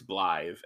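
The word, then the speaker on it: Blaidd: Yeah, the double D is a separate letter from the regular D, and it makes a TH sound. Yeah. – Nah, that's cool. That's cool. – So it literally like rhymes with tithe. It's blithe, and it's Welsh for wolf.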